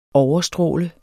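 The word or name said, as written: OVERSTRÅLEDES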